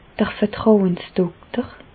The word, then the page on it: d'r Vertrauensdokter: Bas Rhin; Reichshoffen